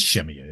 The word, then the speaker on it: Shemya: It is Shemya, isn't that how you pronounce it? – Yep.